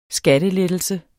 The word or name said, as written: skattelettelse